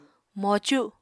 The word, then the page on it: mocu: Apatani